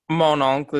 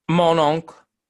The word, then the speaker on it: Mon Oncle: How do you pronounce it so I do it the same? – Mon oncle. – Mon oncle. – Mon oncle. – Mon oncle. – I think that's right.